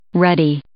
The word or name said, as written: ruddy